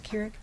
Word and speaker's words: Keurig: I'm not sure I say Keurig correct. Is it Keurig? – Yeah.